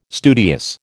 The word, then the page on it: studious: American English